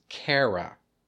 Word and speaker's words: Kara: Her name is Kara. It rhymes with Sarah. It does not rhyme with Mara.